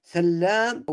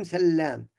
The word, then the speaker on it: سلام: هذه مخففة، أما ما سوى ذلك من الرواة فغالبا يكون سلام.